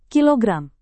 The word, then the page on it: kg: Indonesian